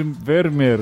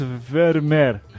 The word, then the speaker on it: Vermeer: A Marina comentou ali, Coisa triste o pessoal do Anticast tentando pronunciar o nome do pintor holandês Vermeer. Até deu eu ouvido, haha, pelo amor de Deus. Ela fala, pessoal, duas letras E têm um som mais agudo, como um I prolongado.